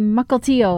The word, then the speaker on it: Mukilteo: I think I pronounced it Mukilteo or something. And she said, oh no, you got to say Mukilteo or else they're going to know you're not from there.